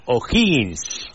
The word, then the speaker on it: O'Higgins: Contra O'Higgins. – Exactamente. – ¿Lo digo bien? – Sí, sí, lo dice muy bien.